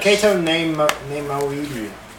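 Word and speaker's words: Cato Neimoidia: Kato Nemoidia. – Yes. – That's how you pronounce it.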